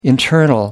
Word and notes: internal: American English

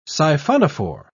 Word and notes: siphonophore: British English

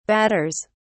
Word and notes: batters: British English

